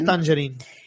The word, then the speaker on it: Tangerine: Sí, es Tangerine.